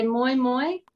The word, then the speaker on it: moi moi: You can say moi moi, which is more if you're a friend, close, mm-hmm.